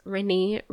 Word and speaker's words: Renna: So since then, I've just allowed persons to pronounce my name how it's spelled. Renna. But I still answered you both names. Renee, Renna.